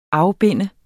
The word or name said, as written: afbinde